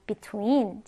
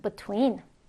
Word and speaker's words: between: between. 자, 두 가지 발음 다 가능하죠? between 또는 between.